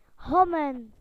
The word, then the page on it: to erase: British English